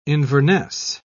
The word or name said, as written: inverness